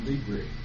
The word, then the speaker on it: Libre: I like the Spanish, L-I-B-R-E, Office, Libre.